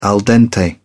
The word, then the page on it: al dente: British English